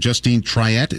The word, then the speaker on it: Justine Triet: Is it Justine? The woman's name, Justine Triet? Is that how you say it? – I think that's right, yeah.